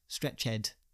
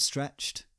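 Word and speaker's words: stretchèd: I'm saying stretched because it's got a little accent above the E, and I like to pronounce that ed, stretched. Now, I know some people would just go with stretched, each to their own, but I like to add a bit of flair in there.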